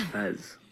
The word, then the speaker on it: Pez: Oh, it's Pez. It's like Pez, only sweeter, baby.